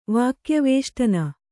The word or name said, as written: ವಾಕ್ಯವೇಷ್ಟನ